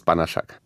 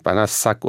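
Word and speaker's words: Banaszak: Also richtig in der polnischen Aussprache ist es Banaschak. – Eigentlich gar nicht so schwer. – Einige kennen ja, also gerade im Ruhrgebiet, wo ich herkomme, wissen, SZ im Polnischen wird wie SCH ausgesprochen. Wohingegen es im Ungarischen genau anders wäre. Da wird es dann irgendwie Banaschak oder irgendwie so etwas.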